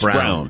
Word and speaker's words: Braun: It's spelled Braun. But it's pronounced but it's Brown. Brown.